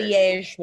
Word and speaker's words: Liège: Liège waffle is how it's pronounced.